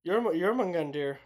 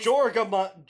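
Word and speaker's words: Jörmungandr: Jormungandr. – Hey. Thank you. Who is... – Jorgamund.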